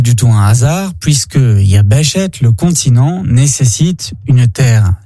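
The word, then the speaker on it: Yabeshet (יבשת): Il se dit en hébreu « Yabeshet ». Yabeshet s'écrit de la manière suivante, Yod, Bet, Shin, Tav, Yabeshet.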